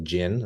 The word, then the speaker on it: djinn: and in Islamic tradition called the Jinn. It's spelled D-J-I-N-N.